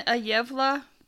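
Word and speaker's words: Gävle: I don't know if you tried or looked it up, but well, it's a Yevla Yevla. I looked it up. Oh, good. It's spelled G A V L E, but the pronunciation is Y E V L A.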